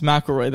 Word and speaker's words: McElroy: Yes, McElroy. That's how you say it. I was thinking. – That's what he replied. He said, like the golfer.